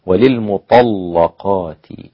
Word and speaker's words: وللمطلقات: إذا تغلظ هذه اللام في رواية ورش، فيصير النطق بها وللمطلقات هكذا. وللمطلقات طبعا عند باقي القراء بترقيق اللام على أصلها.